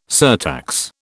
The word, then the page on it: surtax: British English